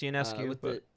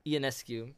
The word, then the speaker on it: Ionescu: It's actually pronounced Ionescu, Ionescu from the New York Liberty.